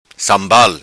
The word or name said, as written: Sambal